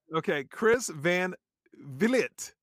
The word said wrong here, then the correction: Van Vliet: It's Van Vliet. – Van Vliet. – Chris Van Vliet. I don't know why my ancestors spell it the way they did, but that I should be an E. V-L-E-E-T would make so much more sense.